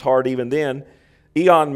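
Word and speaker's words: eon (αἰών): The word world here means eon, or it's pronounced eon, and it means this.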